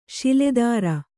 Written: ಶೂಲಧರ

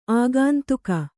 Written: ಆಗಂತುಕ

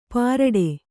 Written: ಪರಡೆ

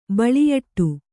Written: ಬಳಿಯಟ್ಟು

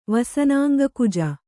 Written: ವಸನಾಂಗಕುಜ

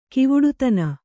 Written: ಗವುಡತನ

